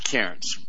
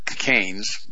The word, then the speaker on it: Cairns: You know, there's a place in Australia called, it's called Cairns. It's spelled C-A-I-R-N-S. And you pronounce it Cairns. And a lot of people call it Canes, but it's pronounced Cairns when you're in Australia.